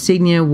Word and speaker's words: Signe: But on August 11th, 1912, 31-year-old, and so her first name is a name that I was not familiar with how to pronounce, S-I-G-N-E, but I did look it up, and it's Signe.